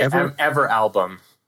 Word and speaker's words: Everalbum: I would say Everalbum. – Everalbum. Thank you. – My interpretation. I had never heard of it either. – That is okay. Yep, I think that is the correct way to say it.